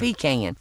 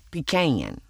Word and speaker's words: pecan: Well, it's spelled P-E-C-A-N, pecan. And I actually was doing a show with Mr. Jimmy, President Jimmy Carter, and he pronounces it pecan. And I said, now, Mr. Jimmy, you and I were raised 35 miles from each other. Maybe he called it pecan. I said, and I call it pecan. So what's going on here? He said, well, Paula, there's actually 4 correct pronunciations.